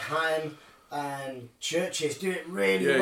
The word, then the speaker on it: Haim: Like, so, okay, so yeah, it's Haim, like, which is spelled H-A-I-M, like Corey Haim, like, but yeah, it's Haim.